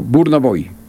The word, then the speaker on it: Burna Boy: Así se pronuncia, mi don Alejo, Burna Boy.